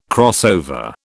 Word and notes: crossover: British English

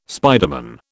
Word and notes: spiderman: British English

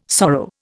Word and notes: sorrow: Indian

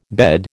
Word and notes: bed: American English

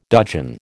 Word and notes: dudgeon: American English

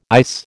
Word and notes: ICE: American English

